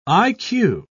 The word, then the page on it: i.q.: British English